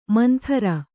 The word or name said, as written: ಮಂದರ